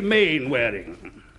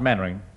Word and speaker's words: Mainwaring: You must be Mainwaring. – Oh, how do you do, Colonel? Actually, it's pronounced Mannering.